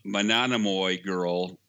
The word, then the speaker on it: Monomoy Girl: I've been pronouncing it wrong all these weeks. I just heard someone say it the other day. Monomoy Girl.